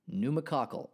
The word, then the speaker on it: pneumococcal: Pneumococcal disease, P-N-E-U-M-O-C-O-C-C-A-L, pneumococcal. I'm probably saying that wrong, although that's what it looks like.